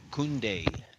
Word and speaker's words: Koundé: Koundé.